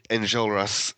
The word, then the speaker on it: Enjolras: Enjolras.